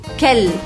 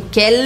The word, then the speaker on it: quel: Quel? É importante que vocês pronunciem quel, fazendo L assim na pontinha da língua e não falem quel. Quel, não é Raquel, é quel. Quel.